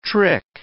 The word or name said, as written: trick